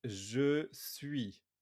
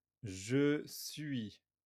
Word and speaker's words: je suis: It's je suis. Je suis.